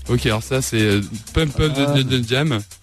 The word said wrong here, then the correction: Pump Up the Jam: Pump Up the Jam, ouais.